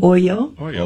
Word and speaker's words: Oyo: And I think it's pronounced Oyo. – Oyo. – O-Y-O.